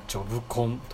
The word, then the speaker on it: 仕事コン: そうなんですか、ね、じゃあ、うん、深谷でですね、今、うんうん、ジョブコンというね、仕事コンと書いてジョブコンと読むものをやってますよ。